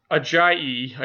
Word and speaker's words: Ajayi: Jay Ajayi, is that how you say it? – Yep. Ajayi, I believe. Ajayi.